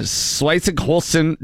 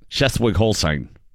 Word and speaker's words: Schleswig-Holstein: Elderly man in uh, Schleswig-Holstein, German, Germany. I don't I don't know if I said that right. I think you pronounced it perfectly. I didn't. Schleswig-Holstein.